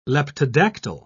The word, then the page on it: leptodactyl: British English